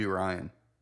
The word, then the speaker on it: Rian: God be Rian. – Might be. – Yeah. – Is it R-Y-A-N? – R-I-A-N? – Rian. – Rian. – I think Rian, there's a Rian.